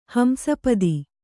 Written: ಹಂಸಪದಿ

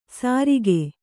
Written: ಸರಿಕೆ

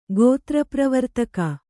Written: ಗೋತ್ರಪ್ರವರ್ತಕ

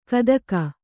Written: ಕುತುಕ

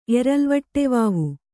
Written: ಎರಲ್ವಟ್ಟೆವಾವು

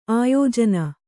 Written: ಆಯೋಜನ

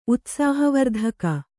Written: ಉತ್ಸಾಹವರ್ಧಕ

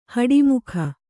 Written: ಹುಟ್ಟಮೂಕ

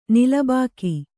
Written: ನಿಲಬಾಕಿ

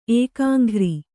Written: ಏಕಾಂಘ್ರಿ

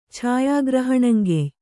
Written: ಛಾಯಾಗ್ರಹಣಂಗೆಯ್

